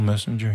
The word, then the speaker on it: Messinger: And, of course, in German, it would be pronounced Paul Messinger.